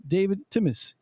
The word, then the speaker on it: David Timis: His name is David Timis. Yes, I got it? – Very good.